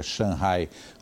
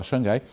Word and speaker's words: Shanghai: Ele pronuncia Shanghai, como é a pronúncia lá na China, aqui normalmente a gente fala Xangai, e o Luca está por lá acompanhando de perto essas informações.